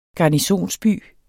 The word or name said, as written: garnisonsby